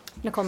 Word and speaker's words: Nicole: Nicole McKittrick. – Would you spell that, please, for me? – N-I-C-O-L-E. McKittrick, spelled M-C-K-I-T-R-I-C-K.